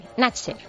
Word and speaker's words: Nachter: Bueno, se escribe Natch de Nacho, N-A-C-H-T-E-R, Natcher, ¿vale?